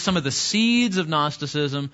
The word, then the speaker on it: Gnosticism: What else do you know about Gnosticism? Does that pretty much exhaust it? Starts with a G. Yeah, you don't pronounce the G.